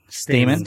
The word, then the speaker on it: Stamann: How do you say his last name? – Stamann. – Stamann. – Stamann? Yeah.